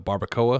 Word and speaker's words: barbacoa: It's it's like a barbacoa.